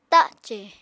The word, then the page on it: tacè: Apatani